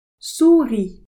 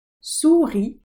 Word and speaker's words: souris: souris, mouse, lapin, rabbit, chèvre, goat, mouton, sheep, vache, cow, chien, chat, souris, lapin, chèvre, mouton, vache.